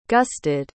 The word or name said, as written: gusted